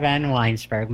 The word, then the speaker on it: Vanwynsberghe: Marsha Vanwynsberghe.